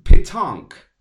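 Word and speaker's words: pétanque: Ja, also das heißt, äh, the proper pronunciation is Petank.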